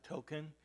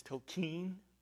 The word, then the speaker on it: Tolkien: please don't pronounce it Tolkien, it's Tolkien.